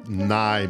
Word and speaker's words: nigh: N-I-G-H. – N-I-G-H, okay. – Yeah, spelled like night, but without the T at the end.